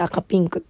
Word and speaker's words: 赤×ピンク: えー、赤ピンクはですね、あの赤かけるピンクって書いて赤ピンクって読むみたいなんですけど、調べた。